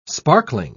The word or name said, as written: sparkling